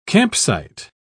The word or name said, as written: campsite